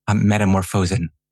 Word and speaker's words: Metamorphosen: But speaking of controversy, we have Richard Strauss, whose piece that you discuss is Metamorphosen. Is that pronounced right? – Metamorphosen.